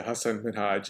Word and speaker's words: Hassan Minhaj: And I do got to give him credit for constantly reminding people how to pronounce his name. He would always say Hassan Minhaj, and he was very particular about, this is my name, this is how you say it, get it right.